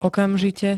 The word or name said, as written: okamžite